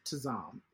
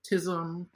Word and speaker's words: Tazam: Because as someone who doesn't have a common name, my name is Tazam. It's two syllables. It's five letters.